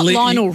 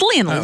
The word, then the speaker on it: Lionel: No, you can't say Lionel. Lionel Richie. Yeah. We're not dancing on the ceiling. – We've got to say Lionel Messi. – It's Lionel. Oh, that's the correct pronunciation.